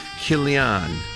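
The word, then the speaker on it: Chilion: 말론을 메일란 이렇게 발음하시고 기련은 킬리안, 킬리안, 메일란, 킬리안 이렇게 연습해 주시기 바랍니다.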